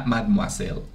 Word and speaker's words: Mademoiselle: sino que hay una fragancia que se llama Mademoiselle. Lo dije bien. – En Mademoiselle. bien. Très bien.